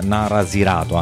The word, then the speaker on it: Narasirato: Si chiamano i Nasarisato, vado a controllare se l'ho detto bene, Narasirato, anzi, questa è la pronuncia giusta, ed escono anche loro lo scorso anno 2012 con Baratoho.